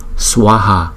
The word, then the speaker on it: Swaha: Chữ S và chữ V đứng chung chúng ta đọc là Swa Swa Swa Swaha Swaha.